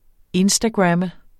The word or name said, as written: instagrammer